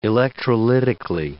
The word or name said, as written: electrolytically